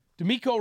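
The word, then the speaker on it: DeMeco: D'Amico Ryans. Is that good for you? – Yes.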